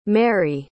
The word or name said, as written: mairie